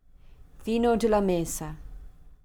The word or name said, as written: vino de la mesa